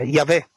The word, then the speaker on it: Yahweh: Che a quanto pare il nome, sai, si pronuncia come Yahweh, insomma il nome del Signore, boh.